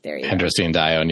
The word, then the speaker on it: androstenedione: A, endostrandione. I don't think I said that right. B, melatonin. C, aldosterone. D, cortisol. E, angiotensin II. – Androstenedione, yeah, which is the answer here.